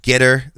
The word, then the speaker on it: Gettr: they talked about a website called Getter. It's spelled G-E-T-T-R.